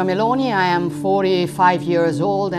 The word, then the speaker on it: Meloni: Giorgia Meloni.